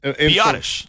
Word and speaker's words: Biadasz: You do add Tyler, I'm going to butcher this last name, Biadasz. I don't even know how to say his last name. Anyways. – Biadasz.